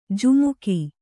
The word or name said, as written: ಝಮಕು